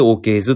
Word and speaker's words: OKs: OKs のライブタイトルがね、 IS が 15 に見えるっていうことで、 OK で 15 って書くんですけど、それで OKs って読むんですけれども、そのタイトルはですね、僕が考えたんですよ。